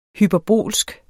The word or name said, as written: hyperbolsk